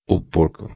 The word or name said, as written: porco